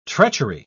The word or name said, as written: treachery